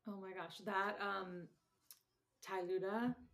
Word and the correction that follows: tlayuda: am I saying that right? – Yeah, tlayuda. Mm-hmm.